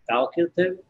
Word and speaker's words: facultative: is it facultative? Am I saying that right? – Facultative, I think.